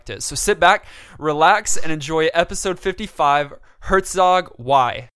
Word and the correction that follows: Herzog Zwei: I think you're mispronouncing it. I think it's Herzog Zwei.